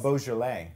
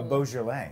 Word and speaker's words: Beaujolais: A Beaujolais. – Oh, say it one more time. – A Beaujolais.